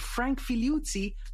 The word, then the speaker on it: Figliuzzi: So without any further ado, huge, huge welcome to Frank Figliuzzi. Hi, Frank. – Ah, you've used the Italian pronunciation.